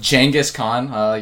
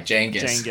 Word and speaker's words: Genghis Khan: Of Genghis Khan. You know, let's say this name right. Genghis. It's Genghis. – From what? – That's his name. It's pronounced Genghis Khan.